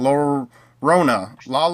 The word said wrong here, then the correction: Llorona: Llorona, The Curse of La Llorona.